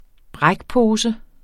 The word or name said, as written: brækpose